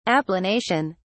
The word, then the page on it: applanation: British English